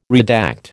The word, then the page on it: redact: American English